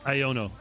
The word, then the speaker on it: Iono: Iono. A-I-O-N-O.